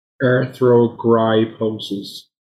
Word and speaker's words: Arthrogryposis: Arthrogryposis.